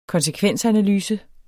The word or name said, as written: konsekvensanalyse